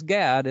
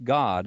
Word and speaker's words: Gad: And again, in the Greek, this word is pronounced as not as Gad. It's pronounced God is the name that she gave to Zilpah's son.